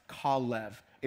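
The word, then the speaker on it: Kalev (כָּלֵב): But in Hebrew, the word Caleb is actually pronounced Kalev. It's a combination of two words, Kal and Lev, right?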